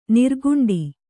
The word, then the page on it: ನೀರ್ಗುಂಡಿ: nīrguṇḍi